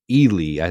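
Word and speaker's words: Ehle: One thing, and this is kind of a correction as well, I think we said the actress's name wrong. Jennifer Ely, I think, is how you pronounce it.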